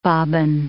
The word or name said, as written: bobbin